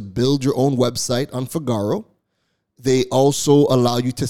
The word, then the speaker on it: Fygaro: Now, Figaro is spelled F-Y-G-A-R-O.